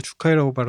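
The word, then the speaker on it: 주카이라고 (樹海): 그 주카이라고 할까 일본말로 주카이라고 발음했는데 이게 한자로 수해.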